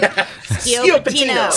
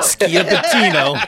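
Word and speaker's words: Schioppettino: We've talked about I'm not even going to try to pronounce it. – Schiappettino. – Schiappettino.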